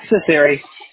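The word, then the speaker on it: Cecere: Ciceri.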